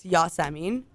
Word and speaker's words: Yasemin: It is actually a very common name. It's Yasemin, which is international. Some of you may have heard it as like Jasmine.